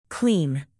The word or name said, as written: clean